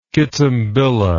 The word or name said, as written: kitambilla